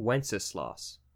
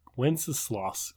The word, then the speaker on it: Wenceslas: King Wenceslas. – Wenceslas. Oh, geez, that is a mouthful. – Or could be pronounced Wenceslas.